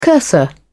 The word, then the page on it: cursor: British English